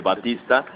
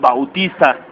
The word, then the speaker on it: Bautista: De Batista. Que curiosamente yo me voy enterando que es Bautista.